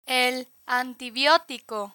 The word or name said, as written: el antibiotico